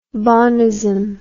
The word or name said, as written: bonist